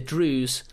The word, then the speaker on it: Druze: It's D-R-U... – As you did with pronunciation. – Pronunciation. It's D-R-U-Z-E. So it's either Druze or Druzy. – I don't know. I think I would have said Druze, but...